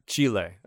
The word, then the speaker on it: Chile: I will be with Andrew Hoags Hogensen, because has decided a trip to Chile is more important than the podcast. – Chile. I think it's pronounced Chile. – Pretentious people say it that way. Yes, yes, you're right.